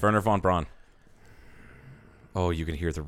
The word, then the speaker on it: von Braun: It's Wernher von Braun. I know. The V is pronounced like an F. Like Volkswagen.